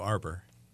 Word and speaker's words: Arbour: There you go, Arbour. – Matthew Arbour. – Rhymes with harbor.